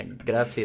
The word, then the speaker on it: grazie: Yeah, grazie is like the Italian. – I just remember I was saying it wrong. They were like, no, it's grazie.